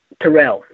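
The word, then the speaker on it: Tyrell: So it's Terrell, that's right. It rhymes with Purell, right?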